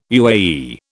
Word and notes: UAE: British English

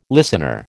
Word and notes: listener: American English

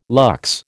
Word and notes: lux: American English

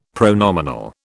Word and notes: pronominal: British English

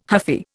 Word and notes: huffy: Indian

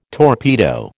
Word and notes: torpedo: American English

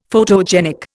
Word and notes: photogenic: Indian

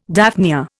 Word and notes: daphnia: Indian

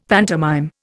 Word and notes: pantomime: Indian

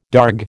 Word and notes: darg: American English

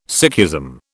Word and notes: Sikhism: British English

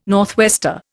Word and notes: northwester: Indian

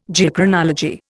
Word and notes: Geochronology: Indian

